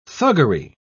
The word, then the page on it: thuggery: British English